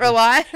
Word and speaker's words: Reali: What does that actually mean at Reali Supply? Can you give us your take on it? – Yeah. So at Reali, I was thinking about... – Sorry, I said that wrong the whole time. At Reali.